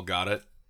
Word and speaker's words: Gadot: But yes, no, I thought, in her name, I'm going to mispronounce a lot, but I think it's actually supposed to be Gal Gadot, I think is the proper pronunciation. – No, she's Israeli. – Gal, I think it's Gal. I like the soft T. I'm with the soft T. I'm with Eddie.